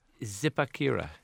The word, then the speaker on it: Zipaquirá: Is it Zipaquirá? – Yeah, exactly.